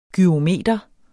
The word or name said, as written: GYROMETER